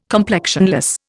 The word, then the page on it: complexionless: Indian